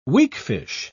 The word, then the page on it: weakfish: British English